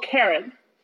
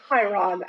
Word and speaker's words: Chiron: They meet a dude called Karen. That's how you pronounce that. It's Karen, you know, because Chiron. Which Percy thinks he says Chiron at first.